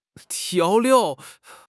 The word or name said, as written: 调料